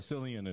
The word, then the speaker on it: Cillian: But we're not in Ireland, so Cillian it is.